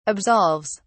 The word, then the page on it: absolves: British English